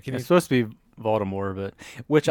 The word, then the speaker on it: Voldemort: But J.K. Rowling said the T is silent. So it is Voldemort.